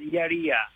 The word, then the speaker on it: Iaria: Iaria.